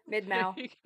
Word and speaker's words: Mid-Mao: Right here, GM Farcaster, mid-Mao. – Mid-Mao. – Rhymes with wow wow, so it's Mounts got memetic wow-wow.